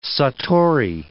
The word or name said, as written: satori